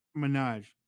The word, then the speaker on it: Minaj: I'm not sure how long they can keep this narrative going, but you know who Nicki Minaj is? Is that how you say her name? – Minaj.